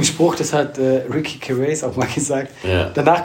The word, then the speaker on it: Gervais: Ich, wir haben ihn jetzt beide falsch ausgesprochen. Gervais, Ricky Gervais.